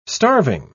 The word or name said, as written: starving